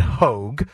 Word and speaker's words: Hogg: That is DNC Vice Chair David Hoag. Clip 13, Phil. By the way, is it Hoag or is it Hog? It's spelled sure it's Hog. – Is it Hog? – H-O-G-G.